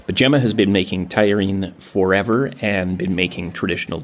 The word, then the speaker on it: tajarin: that is spelled T-A-J-A-R-I-N. My best shot at the pronunciation is tagliarine. That's what people seem to say.